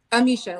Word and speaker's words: Amicia: Amisha, like Alicia, but A-M-I-C-I-A.